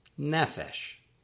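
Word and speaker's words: nefesh (נֶפֶשׁ): Word 27, meaning soul, throat, self, or my favorite translation, life, is pronounced nefesh, nefesh, nefesh. And that does have the accent on the first syllable.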